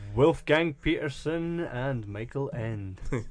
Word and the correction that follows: Ende: I think it's pronounced Ende, by the way. – Is it Ende? I suppose it would be if it was German. Ende. – I think it is Ende.